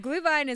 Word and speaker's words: Glühwein: Glühwein is mulled wine. – Okay. – And that's just how you say it in German.